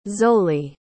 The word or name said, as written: zolle